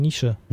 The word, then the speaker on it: Niche: Ja, also N-I-C-H-E. Wie würdest du das aussprechen? Nische.